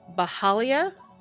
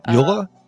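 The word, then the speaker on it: Byhalia: We've got a beaver named Bahalia. I'm probably butchering the name. – Yola? – It's B-Y-H-A-L-I-A. – Oh, okay. Yeah. Definitely not Yola.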